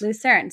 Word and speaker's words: Lucerne: And last you mentioned you were in Lucerne. Did I pronounce that right? – That's correct. – Lucerne.